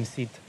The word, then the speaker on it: Suidhe: But Seat is spelled... S-U-I-D-H-E. There's your Gaelic/Celtic languages for you. It's a generic hill name, Seed, and associated with noble people or saints.